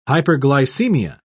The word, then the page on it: hyperglycemia: British English